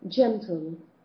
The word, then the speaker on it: gentle: But also, let's see, for example, gentle. So at the beginning of a sentence, sorry, gentle and with the vowel E, gentle, you have to pronounce like this. Eh? This is sh. Gentle.